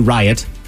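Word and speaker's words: Ryatt: A summer camp counselor shared the names of some triplets: Riot, Rage, and Kathleen. – I think Kathleen will be the most successful. – I think she will. But don't worry, they spelled them different. It's R-Y-A-T-T, Riot. R-A-Y-G-E, Rage.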